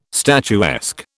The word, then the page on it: statuesque: British English